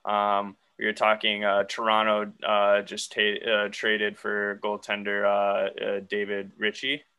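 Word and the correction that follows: Rittich: I believe is how you say his last name. – Riddick, I think.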